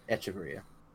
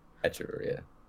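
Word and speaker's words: Echeverría: Echeverria. – No, he did that one right. – Almost. – A little hard on the H. – Yeah, you don't pronounce the H. Echeverria. – Echeverria.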